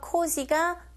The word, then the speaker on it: cozy: cozy 하실 때 우리말처럼 그냥 지읒이 아니라 여러분 cozy 벌이 지나갈 때 나는 소리처럼 cozy. 이걸 느끼시면서 발음하셔야 돼요.